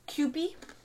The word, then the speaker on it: Kewpie: Yeah, I decided to put some Kempi. What is what? Kewpie? Oh my god, I'm K-E-W-P-I.